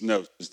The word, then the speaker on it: gnosis: The second word is knowledge, and, and you know the word is it's, it's gnosis. It starts with a G, but it's, it's gnosis. The G is silent.